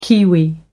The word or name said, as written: kiwi